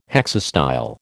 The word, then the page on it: hexastyle: American English